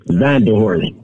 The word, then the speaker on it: Vanderhost: Vandal Horse.